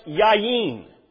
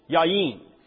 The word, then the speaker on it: yayin: It's the word, it's pronounced yayin, yayin. And it means wine.